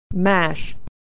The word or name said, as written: mash